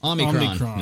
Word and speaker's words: Omicron: Omicron. That's right there. – Omicron. Okay. So here's my concern with Omicron and concern with future variants. – Sounds like a transformer. – It does. A little. Omicron.